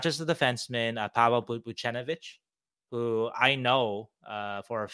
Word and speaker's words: Buchnevich: Buchnevich wasn't on there.